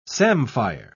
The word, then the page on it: samphire: British English